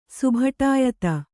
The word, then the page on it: ಸುಭಟಾಯತ: Kannada